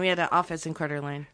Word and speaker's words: Coeur d'Alene: Coeur d'Alene. You spell it C-O-E-U-R and then space and then a D and a little French uh-huh. dashy thing and then A-L-E-N-E. Coeur d'Alene.